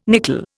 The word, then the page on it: nicol: Indian